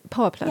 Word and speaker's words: Power Plush: Ich finde, ihr passt ja schon, also ihr habt ja auch den, ist es eigentlich Powerplush ausgesprochen? Wir haben da immer eine Powerplush, ja.